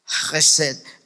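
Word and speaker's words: Gesed (חֶסֶד): Y algunos que eh, hablan correctamente hebreo, eh, la pronunciación sería Gesed.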